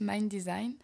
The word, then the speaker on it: MNDSGN: Alors après, ça va être Mind Design, alors ça s'écrit MNDSGN, mais ça se prononce Mind Design.